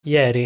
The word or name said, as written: Ieri